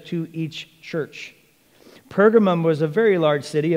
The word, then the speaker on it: Pergamos: But what of Pergamum? Let's bring back our handy map up here. And so we are in, as this map would say, Pergamos, which is an alternative translation and pronunciation for Pergamum.